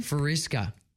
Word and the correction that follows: fuchsia: What is that? Frisca? – Oh, no, fuchsia. – Fuchsia. – Fuchsia. – Wow, I was way off on that one.